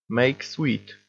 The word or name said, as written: make sweet